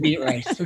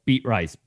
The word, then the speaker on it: Beatrice: Okay, so any listener that is not from Nebraska or Iowa, the reason we're making fun of it is because Beatrice is spelled Beet Rice, B-E-A-T-R-I-C-E. Yeah. So that's why it looks like Beet Rice.